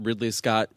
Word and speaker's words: Ridley Scott: So, and then it occurred to me that day that Ridley Scott rhymes with diddly squat.